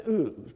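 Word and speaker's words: Uz (עוּץ): Yeah, the Hebrew word would be pronounced Uz, but we're going to get into some Hebrew words here in a little bit.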